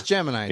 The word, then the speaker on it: Gemini: Isn't it the G Mini? – Is it the G Mini? Did I say it wrong? – I don't know. – No, it's Gemini. – Is it Gemini? – You could say G Mini, I guess, but it's G-E-M-I-N-I.